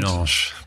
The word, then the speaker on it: Dudelange: Vorhin waren wir in, und jetzt werde ich es wahrscheinlich wieder falsch aussprechen, Dudelange. Wie heißt der Ort? Dudelange.